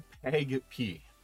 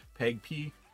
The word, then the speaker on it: PAgP: Okay, first negotiation protocol, auto negotiation protocol, is pronounced PEG-P. I have a problem saying that, PEG-P. It's written as capital P, capital A, lowercase g, capital P.